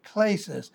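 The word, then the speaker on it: klesis (κλῆσις): So this word call, klesis, K-L-E-S-I-S, the E is pronounced as a long A, klesis.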